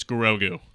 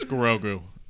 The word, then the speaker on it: Grogu: and they all looked at me and they're like, "It's Grogu." Okay, I was like, "Whatever, you guys know who I'm talking about." And they're like, "Say his name. It's Grogu," and I'm like.